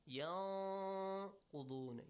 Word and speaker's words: ينقضون: ولا ينقضون الميثاق. Echo the sound of thing. Repeat it. الذين يوفون بعهد الله ولا ينقضون الميثاق. – أحسنتم. ينقضون. You hide the sound but extend it also.